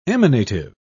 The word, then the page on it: emanative: British English